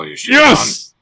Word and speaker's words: Yoshida-san: but Yoshida-san, I think. – I might have got it, actually. – Oh, Yoshida-san.